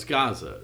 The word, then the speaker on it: Gaza: Well, no, it's Gaza.